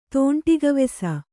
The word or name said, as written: ತೋಂಟಿಗವೆಸ